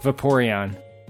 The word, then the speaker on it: Vaporeon: I think you're pronouncing that wrong. I can give the right pronunciation. – Please. Well, no. Okay, please. – Yeah. Vaporeon. – Vaporeon.